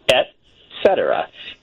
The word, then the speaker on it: et cetera: There's like four major pronunciations, and only one of them really is the best pronunciation, and that is et cetera. It's E-T, not E-X at the beginning.